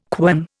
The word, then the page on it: quern: Indian